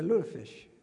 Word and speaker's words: lutefisk: Yeah, lutefisk.